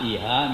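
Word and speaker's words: Īhā: It's a, with long A, Iha means desire.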